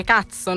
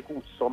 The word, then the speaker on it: Kutso: Ma allora, il nostro nome, per chi non ci conosce, si scrive Cuzzo, qui uno lo leggerebbe Cusso, ma questo era il modo che io avevo di scrivere le parolacce sui banchi, quindi io questo Cuzzo, appunto, invece di leggerlo Cusso, lo facevo diventare all'americana, la U la facevo diventare A, e quindi Cazzo.